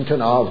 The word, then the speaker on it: Cana: Cana, as most of us would pronounce it, but it's actually Cana in Greek.